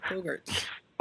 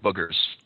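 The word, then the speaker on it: boggarts: Bogart. How do you say that? Bogarts. – Bogarts. – Yeah. Bogarts. There you go. – Not boogers. – Not boogers. No, no, no.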